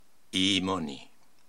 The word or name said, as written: Iimoni